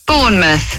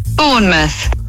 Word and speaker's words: Bournemouth: Bournemouth. Bournemouth.